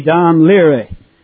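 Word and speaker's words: Dún Laoghaire: They even take the Irish name for Dublin and say the proper pronunciation should be Dan Leary.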